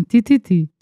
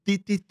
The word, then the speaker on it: Tititi: Tititi, tem que falar assim, Tititi. Tititi, tititi.